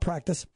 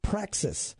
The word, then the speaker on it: praxis: And that, and yes, I didn't mispronounce that, not practice, praxis.